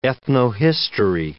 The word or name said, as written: ethnohistory